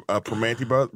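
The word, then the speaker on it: Primanti: I'll take you to Permanente Brothers. Am I saying that right? – Yeah, you're saying it right, but I'm good. I'm good. Yeah, Permanente Brothers, you know, it's funny.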